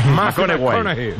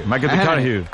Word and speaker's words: McConaughey: Que hay que ir corrigiendo la pronunciación. – McConaughey. – McConaughey.